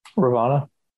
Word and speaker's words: Ravonna: Yeah, she has Ravonna, or say her name for me. – Ravonna. – Ravonna. I have it spelled. I'm fucking saying it wrong.